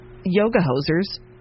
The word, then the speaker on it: Yoga Hosers: And Yoga Hosers.